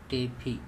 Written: tepHi

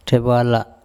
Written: Thebala

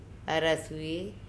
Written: araSue

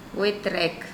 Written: oe trEk

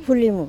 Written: pHulimu

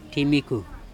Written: ThimikHu

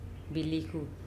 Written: bilikhu

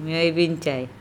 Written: meobincae

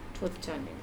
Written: THut conne

